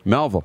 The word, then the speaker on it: Melville: Trevor, Trevor Crusher says George and Sid Abel from Melville. Did I say that right? – Melville. – Melville. – You nailed it.